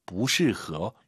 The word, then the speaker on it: 不适合: 아니 불자 사성이에요. 근데 뒤에가 사성이면 이성으로 바뀌죠. 不适合, 不是 아니에요. 不适合, 이것도 발음 한번 다시 들어볼게요.